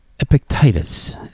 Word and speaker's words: Epictetus: I can't say it right. Epictetus. That's it.